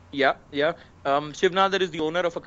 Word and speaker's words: Shiv Nadar: Shiv Nadar. I don't know if I'm pronouncing it right. – Yeah. The school is called Shiv Nadar.